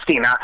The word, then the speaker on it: Stina: But Stina, it looks like Stein, but it's pronounced Stina.